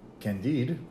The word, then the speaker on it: Candide: The satirical Odyssey, Candide, was written...